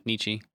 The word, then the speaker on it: Nietzsche: Oh, yeah, Nietzsche.